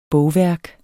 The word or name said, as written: bogværk